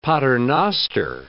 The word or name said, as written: paternoster